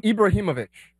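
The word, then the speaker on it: Ibrahimović: How do you say his last name? – Ibrahimovic.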